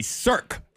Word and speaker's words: Cirque: It's a Cirque. Yeah. C-I-R-Q-U-E.